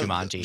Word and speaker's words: Jumanji: Residents of a small South Carolina town are finding themselves in the middle of a real-life game of Jumanji. Is that how you pronounce that? – Jumanji.